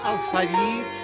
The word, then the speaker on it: алфавит: Нельзя сказать алфавит, а можно алфавит. – Кто говорит алфавит, неверно говорит.